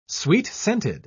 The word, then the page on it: sweet-scented: British English